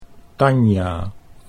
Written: Tanja

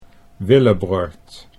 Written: Willibrord

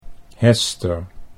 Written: Hester

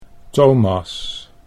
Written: Thomas

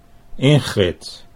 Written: Ingrid

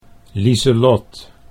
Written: Liselot

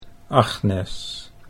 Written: Agnes

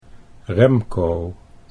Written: Remco

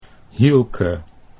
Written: Hielke

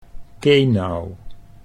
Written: Kenau

